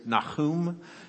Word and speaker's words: Nahum: I think the correct pronunciation, if you can forgive my Hebrew here, is Nahum.